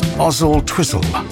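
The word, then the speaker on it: Oswaldtwistle: Gordon Stone says, it's pronounced Ozzle Twizzle without the W.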